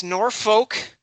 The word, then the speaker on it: Norfolk: It's Norfolk. – There is no R in the second syllable.